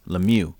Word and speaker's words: Lemieux: Lemieux.